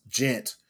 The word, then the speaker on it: Djent: But then this quote unquote new subgenre spelled DJENT, pronounced gent, came along and they threw them into that category and they're like, we're not gent.